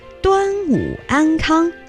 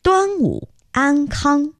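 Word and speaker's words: Duanwu Ankang (端午安康): Duanwu Kuaile, or Duanwu Ankang, wishing you health during the Duanwu Festival. Duanwu Ankang.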